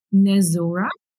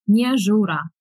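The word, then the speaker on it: Nezhura: So, in the US, I go by Nezura, but in Ukraine, it would be Nezura.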